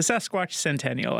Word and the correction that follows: Sesquicentennial: I believe is how you pronounce it. – Yeah, we all meant what, you know? So the Sesquicentennial, I think, but that is a $5 word for sure.